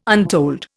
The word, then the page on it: untold: Indian